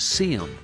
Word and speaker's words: Seyom: His first name is spelled S-E-Y-O-M and pronounced Seam.